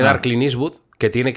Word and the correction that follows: Clint Eastwood: que dar Clint Eastwood por si gana el que Debe dar, que debe dar Clint Eastwood...